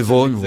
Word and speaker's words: Vaughan: Enfin, je crois que ça se prononce Vaughan. – Oui, Vaughan, oui, c'est vrai.